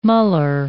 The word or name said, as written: muller